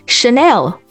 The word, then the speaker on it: Chanel: Chanel。